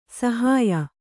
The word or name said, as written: ಸಾಹಾಯ್ಯ